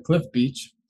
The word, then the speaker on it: Cliff Beach: All the streaming platforms, you'll find my music under Cliff Beach, just like it's spelled. Cliff like the cliff, beach like the beach. If you're pronouncing it any different, you're pronouncing it wrong.